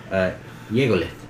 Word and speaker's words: Yegolith: Yegolith. Yegolith would be his daddy. – Okay. Doesn't seem like a very friendly person. – Yingolith. I said that wrong.